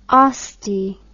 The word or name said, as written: oste